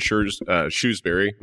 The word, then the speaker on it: Shrewsbury: You're in Shrewsbury.